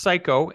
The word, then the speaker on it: Cayko: And it's actually pronounced psycho.